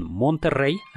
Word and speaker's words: Monterrey: Y por supuesto, en California existe un Monterrey, a mí me gusta pronunciarlo así, con doble R, porque aquello era territorio mexicano.